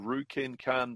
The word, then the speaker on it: Ryukenkan: It's called Ruken Kan Dojo. That's spelled R-Y-U-K-E-N-K-A-N Dojo, Ruken Kan Dojo.